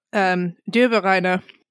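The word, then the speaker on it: Döbereiner: Did I say that right? Dobreiner? You speak German. – Johann Wolfgang Dobreiner.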